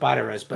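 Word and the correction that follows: Biadasz: I think it's Biadish. – Biadish, yes, you're right.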